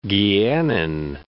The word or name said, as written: Guianan